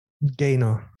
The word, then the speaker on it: Gainer: Spricht man das Gainer aus? – Gainer, von to gain.